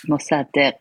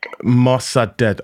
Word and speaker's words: Mossadegh: Mossadegh. – Mossadegh.